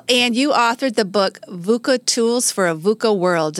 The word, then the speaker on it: VUCA: And you said it right, VUCA. Right. So sometimes people even stumble over VUCA, yeah. – So it is VUCA, which sounds harsh.